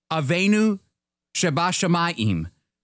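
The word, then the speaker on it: Avenu Shebashamayim (אבינו שבשמים): Our Father in heaven, Avenu Shebashamayim. Our Father who is in heaven. That's how you say it in Hebrew.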